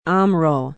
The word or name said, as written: omro